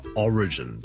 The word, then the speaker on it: origin: Single origin 咧，其實咧第一件事咧就係咧 single 後面個 lm 咧好多時候咧都可以冧埋落去個 origin 度，所以咧我 single origin 個 l 冧埋落去 origin 變咗 single origin。 OK, that's number one。 Number two 咧，如果讀成 origin 咧，就會怪怪地啦，因為個重音其實 origin O-R-I-G-I-N 個來源咧，我哋個重音係第一個音節嘅，所以 single law origin。